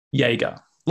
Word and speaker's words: Jaeger: Jaeger. – Like Jaeger. A Jaeger bomb.